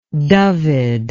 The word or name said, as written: dyfed